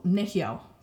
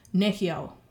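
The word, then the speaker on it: nêhiyaw: So nehyo, for example, Cree. Nehyo. It's three syllables and the emphasis is on the first syllable because that's the third last syllable. Nehyo. And let me clarify.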